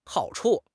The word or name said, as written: hao3 chu5